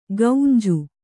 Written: ಗೌಂಜು

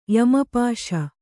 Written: ಯಮಪಾಶ

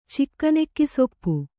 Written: ಚಿಕ್ಕನೆಕ್ಕಿಸೊಪ್ಪು

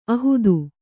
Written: ಆಹಿತ

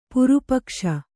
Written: ಪಾರಿಪಕ್ಷ

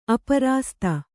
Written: ಅಪ್ರಸಾದಿ

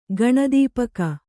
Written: ಗಣದೀಪಕ

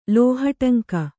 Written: ಲೋಹಟಂಕ

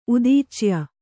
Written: ಉದೀಚ್ಯ

